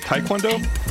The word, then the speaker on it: Taekwondo: So you pronounce it Taekwondo? – Yeah.